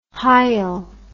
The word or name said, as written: hyal